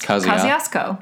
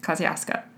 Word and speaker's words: Kosciuszko: But it's pronounced Kosciuszko. – Kosciuszko.